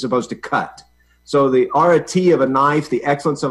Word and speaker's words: arete: You know, excellence, arete, or arete, the way classicists pronounce it.